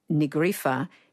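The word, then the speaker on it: Ní Ghríofa: And Nigrifa is sort of two words, N-I with an acute, although it's not called an acute in Irish, and Grifa is G-H-R-I-O-F-A.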